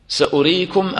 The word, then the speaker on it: سأريكم: سأريكم آياتي، نتأمل كيف هذه الكلمة مرسومة بالواو سأريكم، لكن طبعا الواو ما تنطق هنا، هذا يعني هكذا في الرسم العثماني، والله أعلم بالحكمة من هذا.